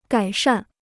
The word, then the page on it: 改善: gǎi shàn